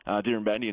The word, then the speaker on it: Dirranbandi: So Bolan, St George, Dirranbandi, and tell me if I'm pronouncing those correctly. – I think you were, yeah, 3 from 3 there.